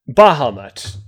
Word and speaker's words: Bahamut: And what I would like to submit is Balmont. Am I saying that right? Balmont? – Bahamut? So, Bahamut. There we go.